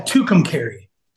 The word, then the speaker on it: Tucumcari: Yeah, Tucumcari. – Tucumcari.